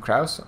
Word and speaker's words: Krouse: You can find me at Steve Krouse on Twitter.